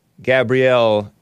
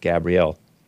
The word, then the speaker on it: Gabrielle: Gabrielle is spelled like Gabriel, but it's pronounced Gabrielle.